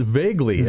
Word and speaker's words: Voegeli: You've uh, got uh, something from uh, Don Voegeli. Am I pronouncing that correctly? – It's and vaguely, vaguely, actually. okay. You are vaguely close.